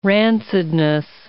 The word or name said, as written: rancidness